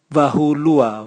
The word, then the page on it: vahulua: Raga